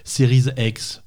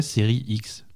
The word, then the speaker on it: Series: Donc, si tu veux le prononcer à l'anglaise, ça serait Series X. – Et Series S. Si tu veux le prononcer à la française, ça serait Série X.